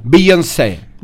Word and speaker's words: Beyoncé: Beyoncé.